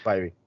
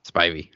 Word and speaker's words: Spivey: Spivey. – Spivey.